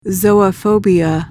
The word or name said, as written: zoophobia